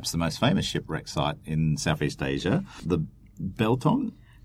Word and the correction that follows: Belitung: So it's pronounced Blitung. – Blitung. – But it's spelt Belitung, and often that is how it's pronounced.